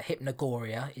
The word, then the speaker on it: Hypnogoria: So, um, and I have to say, if you haven't checked out any of Jim Moon's stuff, uh, Hypnogoria is his name on Twitter.